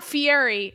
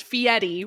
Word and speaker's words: Fieri: Oh, well, it's not Fieri. It is pronounced Fieri with a D, and that's a pet peeve of mine.